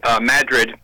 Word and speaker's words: Madrid: I think I told you before, where I went to college in northern New York, we had several towns that were spelled the same as European towns, but pronounced differently. Madrid instead of Madrid was one of them.